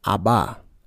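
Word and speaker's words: Abba (אבא): In the modern Hebrew, they would pronounce it Abba, A-B-B-A, Abba.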